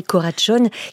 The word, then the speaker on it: Karácsony: ça s'écrit Karácsony. Donc voilà, on dit Gergely Karácsony, qui défend, donc on l'entend, Brunto, une politique écologique et sociale.